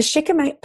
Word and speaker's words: shikimate: So that's spelled S-H-I-K-I-M-A-T-E.